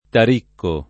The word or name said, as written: Taricco